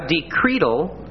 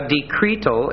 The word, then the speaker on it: decretal: A decretal, and notice it's pronounced with a long E, a decretal is a letter from a pope or church official containing an authoritative decision, or a decree.